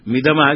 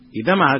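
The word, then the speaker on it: इदम् आह: मिदम आह क्या चीज, मिदम आह क्या है, इदम आह कहना चाहिए, इसे बताएंगे, फिर आपको लगेगा ये तो बड़े विद्वान है बोलते है, हमारी गलती है, ऐसा सोच कर फिर इसे करने लगे।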